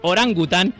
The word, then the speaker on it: orangután: Y le dice, no se dice araután, se dice orangután.